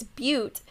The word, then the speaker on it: Butte: It's Butte, but it makes me think of butt, because it's spelled B-U-T-T-E. Like, it looks like butt.